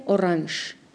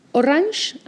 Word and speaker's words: Orange: Hay personas que lo afrancesan mucho y pronuncian orange. – Orange. – En casi toda Alemania se alemaniza esta palabra, que efectivamente viene del francés, y se dice orange.